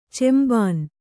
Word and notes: ಚುಂಬನ: cumbana

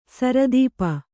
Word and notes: ಸಿರಿತುಪ್ಪೆ: siri tuppe

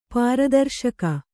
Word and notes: ಪ್ರದರ್ಶಕಿ: kannada

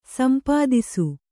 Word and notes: ಸಂಪಾದಿಸು: sampādisu

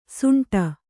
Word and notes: ಸುಂಟ: suṇṭa

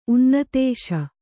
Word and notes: ಉನ್ನತೇಶ: Kannada

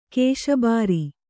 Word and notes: ಘೋಷಬಾರೆ: ghōṣa bāre

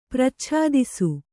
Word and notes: ಪ್ರಚ್ಛಾದಿಸು: prachādisu

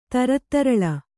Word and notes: ತರತ್ತರಳ: Kannada

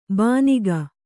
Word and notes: ಬಾನಿಗ: bāniga

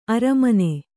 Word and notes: ಅರೆಮಾನ: aremāna

